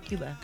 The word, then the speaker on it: Cuba: Is it Cuba or Cuba? – It's actually Cuba. – That's what I say. It's Cuba, right? – Cuba. – You say it correctly.